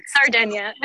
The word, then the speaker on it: Sardinia: Like, it's just like Sardinia. – Sardinia. Okay, like sardines, or is it just like? – Yeah, it's like I think it is pronounced Sardinia,